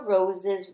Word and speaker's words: rose: So rose has a long O sound. Just like hose. Do you think rose and hose rhyme? Yep, they do, because they end the same way.